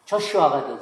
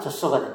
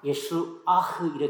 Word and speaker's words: 여호수아: 영어 발음으로는 조슈아가 되죠. 한국식으로 잘못 발음하면 젖자는 젖소가 됩니다. 팀이 미국에 이제 와서 아주 어렸을 때 자기 주위의 친구들 중에는 젖소가 많대요. 여호수아는 젖자는 젖소가 아닙니다. 우리식 발음으로는 여호수아라 하고 미국식 발음으로는 조슈아라 그러고 원래 히브리식 발음으로는 예수아흐 이렇게 있습니다. 뒤에다 액센트를 줘요.